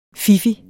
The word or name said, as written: fiffig